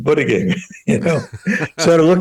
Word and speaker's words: Buttigieg: I said, "Buttigieg." It's Buttigieg. Okay, Buttigieg.